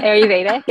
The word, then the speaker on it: Ayurveda: You got into your Ayurveda. I hope I'm saying that right. – Yeah. Ayurveda.